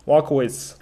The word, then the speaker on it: Wolkowicz: 你的姓怎么读？应该 Peter。 – 这个叫呃 Walkowitz。 – Walkowitz。 – Walkowitz。对，就是是一个波兰的名字。